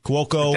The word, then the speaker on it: Cuoco: Cuoco.